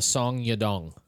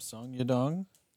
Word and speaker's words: Song Yadong: Am I saying that right? – Yep. Saw your dong? – Yep. Song Yadong. – Nope, Song Yadong. – I think that's how you say right.